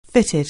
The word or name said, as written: fitted